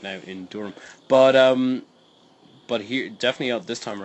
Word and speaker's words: Durham: Am I saying that correctly? Durham or Durham? – Durham.